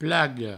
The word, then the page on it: plaga: plago